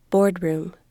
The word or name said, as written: boardroom